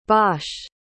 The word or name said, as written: bosch